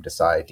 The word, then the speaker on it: Desai: My handle on Instagram is my first name, the letter I, my last name, Desai, D-E-S-A-I, so Mitchell I Desai.